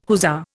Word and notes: hussar: Indian

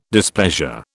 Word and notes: displeasure: British English

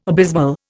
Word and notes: abysmal: Indian

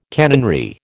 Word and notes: canonry: American English

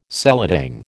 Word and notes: seladang: American English

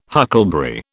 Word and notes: huckleberry: British English

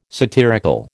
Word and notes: satirical: American English

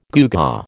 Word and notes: gewgaw: American English

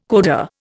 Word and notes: coda: Indian

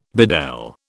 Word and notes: bedel: British English

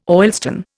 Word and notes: oilstone: Indian

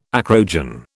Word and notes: acrogen: British English

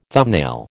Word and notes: thumbnail: American English

